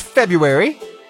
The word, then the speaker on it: February: It's February. But we say it without something in it. Usually we don't pronounce that extra little letter in there right after the B.